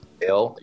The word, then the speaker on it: Bail: Who is it? Bail? How do you say it? – Bail, yep.